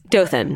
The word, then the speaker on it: Dothan: Dothan? – Dothan. – How do you spell it? – D-O-T-H-A-N.